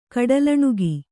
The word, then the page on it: ಕಡಲಣುಗ: kaḍalaṇuga